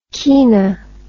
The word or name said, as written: quina